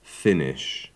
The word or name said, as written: Finnish